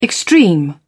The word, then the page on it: extreme: British English